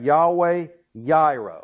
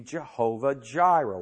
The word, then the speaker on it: Yahweh Yireh: We have Yahweh Yireh, or if you want to pronounce it with a J in English, that would be Jehovah Jireh.